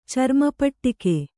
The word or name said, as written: ಚರ್ಮಪಟ್ಟಿಕೆ